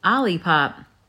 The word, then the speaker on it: Olipop: Olipop. – I would pronounce that O-lip-op. – Okay.